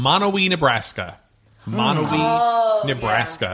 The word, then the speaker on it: Monowi: Oh, it's spelled M-O-N-O-W-I, okay. – Monowee.